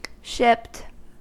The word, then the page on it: shipped: American English